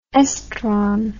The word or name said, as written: estron